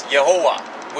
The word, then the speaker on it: Yehovah: It's not Jehovah, it's Yehovah, which is a pronunciation of Yehovah, which is what happens when you take the vowels of Adonai and put them on the letters YHWH in Hebrew.